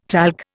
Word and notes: talc: Indian

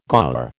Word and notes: gaur: American English

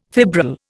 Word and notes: fibril: Indian

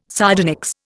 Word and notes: sardonyx: Indian

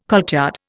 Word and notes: courtyard: Indian

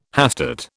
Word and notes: hastate: British English

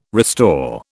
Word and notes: restore: British English

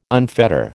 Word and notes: unfetter: American English

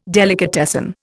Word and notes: delicatessen: Indian

